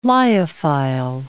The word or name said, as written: lyophile